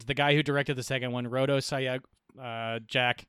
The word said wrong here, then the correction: Sayagues: However, it's actually pronounced Sayagues.